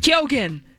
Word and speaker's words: Keoghan: And Paul Mescal, and forgive me for mispronouncing his name, Barry Keoghan. – Keoghan. – Thank you.